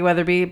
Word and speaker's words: Weatherbee: I can be found on Twitter these days at, it's just my name, but it's spelled weird because my name has too many letters. So it's at Jackie Weatherby, but the Weatherby is spelled wrong. So it's @JACKIEWEATHERBE. single E at the end.